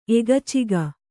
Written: ಎಗಚಿಗ

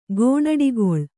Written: ಗೋಣಡಿಗೊಳ್

